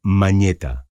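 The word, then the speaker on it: mañeta (монета): La palabra manat proviene del ruso moneta, que significa moneda y se pronuncia mañeta.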